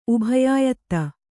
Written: ಉಭಯಾಯತ್ತ

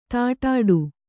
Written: ದಡ್ಡಿ

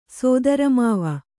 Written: ಸೋದರಮಾವ

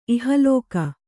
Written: ಇಹಲೋಕ

